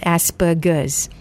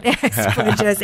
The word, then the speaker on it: Asperger's: So, and it's pronounced Asperger's. – That's right. – Not Asperger's, as some of us would, you know, pronounce it with the soft G.